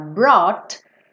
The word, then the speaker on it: brought: GHT, é, brought, porque a pronúncia, você ignora essa parte, tá? Do GH.